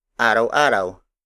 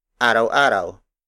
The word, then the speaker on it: araw-araw: For example, araw-araw, araw-araw, araw-araw. Araw-araw is spelled as A R A W hyphen A R A W.